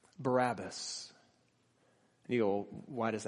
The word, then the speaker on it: Barabbas: In English, which is taken from the Greek translation, we call him Barabbas. But in Aramaic, the language that they would have been speaking, his name is pronounced Barabbas.